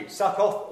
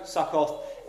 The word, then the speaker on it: Sukkot: Sukkoth or... Well, I looked up the pronunciation just in case, and I got Sukkot. But it's, I don't know which one's correct. Sukkot, Sukkoth, S-U-K-K-O-T, for those writing notes.